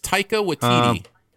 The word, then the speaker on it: Taika Waititi: And I will never know how to pronounce the director's name, but I researched it for my review, so I can tell you if you want. – Oh, please do. – It's Taika Waititi.